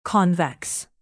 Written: convex

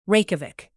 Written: reykjavik

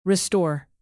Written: restore

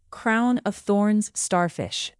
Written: crown-of-thorns starfish

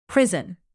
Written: prison

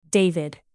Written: david

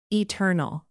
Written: eternal

